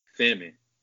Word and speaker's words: salmon: Salmon. The L is silent.